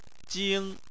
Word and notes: jing1: chinese

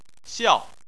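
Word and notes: xiao: tone 4

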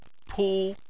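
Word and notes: pu: tone 1

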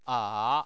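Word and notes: a: tone 3

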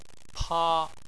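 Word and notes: pa1: tone 1

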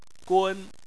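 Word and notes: gun1: tone 1

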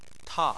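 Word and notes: ta: tone 4

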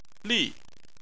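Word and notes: li: tone 4

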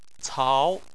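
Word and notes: cao: tone 2

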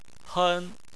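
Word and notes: hen: tone 1

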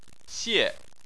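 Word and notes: xie: tone 4

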